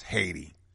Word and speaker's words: Haiti: That's how you should pronounce Haiti. You should pronounce it correctly, as the sisters and brothers have told me. Haiti.